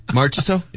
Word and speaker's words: Marchessault: Marchessault. – So they would select Marchessault. How do you pronounce it? Marchessault? – Marchessault. – Yes.